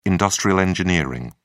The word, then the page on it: industrial engineering: British English